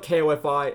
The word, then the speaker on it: Ko-fi: Well, it might be coffee, but it's spelled K-O-F-I, and that's weird, so I say it Ko-fi.